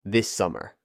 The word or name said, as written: this summer